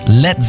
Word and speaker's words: Led: Led Zeppelin. Das A in Led ließ er einfach weg, damit es nicht wie Blei ausgesprochen wird.